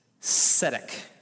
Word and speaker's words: tzedek: The Hebrew word for righteous is tzedek. Okay, the T is silent.